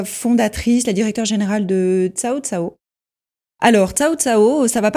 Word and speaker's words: Cao Cao: pourquoi Cao Cao et non pas KO KO alors parce qu'en fait, Cao Cao a une signification, c'est une personnalité mythique en Chine qui, lorsqu'on l'appelle, il arrive. Donc c'est pour ça qu'on tient à la prononciation de Cao Cao, parce que ça a une signification.